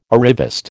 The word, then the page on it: arriviste: American English